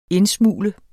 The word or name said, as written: indsmugle